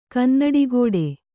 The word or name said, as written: ಕನ್ನಡಿಗೋಡೆ